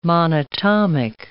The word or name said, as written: monatomic